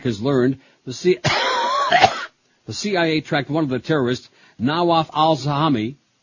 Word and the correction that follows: Nawaf al-Hazmi: Did I say that right? No. Nawaf al-Hazmi, as he flew from the meeting to L.A.